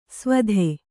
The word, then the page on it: ಸ್ವಧೆ: Kannada